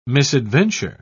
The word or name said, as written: misadventure